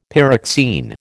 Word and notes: pyroxene: American English

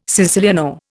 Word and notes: siciliano: Indian

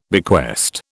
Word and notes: bequest: British English

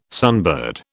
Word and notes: sunbird: British English

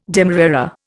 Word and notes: demerara: Indian

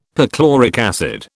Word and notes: perchloric acid: British English

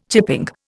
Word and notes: chipping: Indian

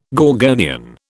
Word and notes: gorgonian: British English

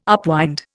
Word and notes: upwind: Indian

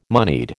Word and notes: moneyed: American English

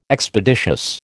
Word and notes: Expeditious: American English

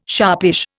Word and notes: sharpish: Indian

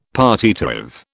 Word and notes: partitive: British English

